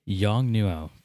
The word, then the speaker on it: Yongnuo: Was it a Yangundo flash, or was it a Canon? – Oh, gosh. Really? You're going to do that again? Yongnuo. Pronounce it right.